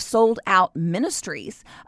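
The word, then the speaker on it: Souled Out Ministries: as well as the foundation of Sold Out Ministries. So let me explain. It's spelled like your soul, S-O-U-L-E-D, Sold Out Ministries.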